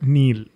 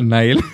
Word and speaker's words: Nile: I know people who call it Neil. Yeah. Who pronounce Nile as Neil. – I don't think Robert Patterson's character is going to be that happy. – Yeah. Okay, you continue. – Anyway, so it is called Nile.